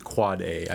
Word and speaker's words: AAAA: And you do pronounce it Quad A.